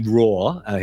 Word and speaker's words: Rohr: Richard Rohr, his surname is pronounced is spelled R-O-H-R.